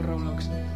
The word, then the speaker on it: Roblox: No, es que yo juego mucho al Roblox.